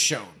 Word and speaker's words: Sjón: I believe it's Sjón. – Yeah. – That's how he's pronounced it in interviews.